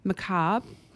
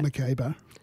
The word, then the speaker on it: macabre: Well done. That's very good. – I know. I was going to say it like I saw it and I thought, no, it's not quite like that. – Macabre.